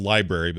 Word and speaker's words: LBRY: You can go over to the website, and I'll have a link to this, or you can go directly to, it's called Library, but it's spelled LBRY.TV.